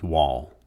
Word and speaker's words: Wall: When I was born on June 3rd, 1973, my last name at birth was Wall, W-A-L-L, Robert Roy Wall.